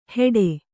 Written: ಹೇಟೆ

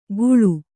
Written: ಘುಳು

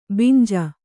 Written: ಬಂಜೆ

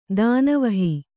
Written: ದಾನವಹಿ

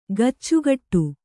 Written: ಕಚ್ಚೆಗಟ್ಟು

